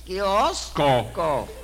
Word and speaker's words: kiosco: Óyeme, entonces, un coquio que se llama kiosco. – Kiosco. Dígalo.